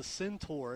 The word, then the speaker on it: centaur: You don't separate the syllables. Okay. Centaur.